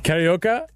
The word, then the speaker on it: Carioca: KBG DJ on WZBC Mofo Radio live Bale Funk Music Funky Carioca. Am I saying that right? Carioca? – Yep.